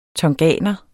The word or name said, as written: tonganer